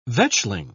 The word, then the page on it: vetchling: British English